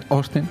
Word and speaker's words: Austin: Permíteme que lo pronuncie bien. Austin. – Con O, ¿no? Eso. – Bueno, la A no la dicen. Me fijé Austin. Y no lo decían Austin.